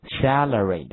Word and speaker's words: salary: 哎，我们说的薪水这个词，最正式的说法就是 salary，对不对？salary，salary，salary。读这个词的时候，大家一定要注意这个发音哈，就是口这个第一个 a 是发那个梅花音的 a，所以说的话口型一定要往两边张。